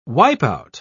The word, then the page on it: wipeout: British English